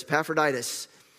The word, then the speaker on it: Epaphroditus: Epaphroditus. Am I saying it right? Yes, Epaphroditus.